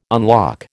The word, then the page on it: unlock: American English